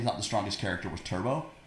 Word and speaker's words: Turbaux: And his name is spelled T-U-R-B-A-U-X. – Yeah. – Turbo.